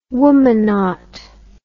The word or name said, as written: womanaut